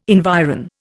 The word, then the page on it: environ: Indian